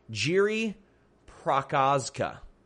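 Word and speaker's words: Jiri Procházka: Prozaka. Prokovska. I know I've pronounced his name correctly, but it's like one of those things, like I can't pronounce it correctly. Jiri Prokazka. Jiri Prokazka defeated Volkan Ozdemir.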